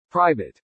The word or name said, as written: private